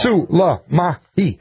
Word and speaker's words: Soleimani: The question about, the reporter asked, Mr. Secretary of State, what about the assertion by General Zarif that the General Salami, I'm sorry, I keep saying it wrong. You know I don't mean to say that wrong. – No. – Suleimani.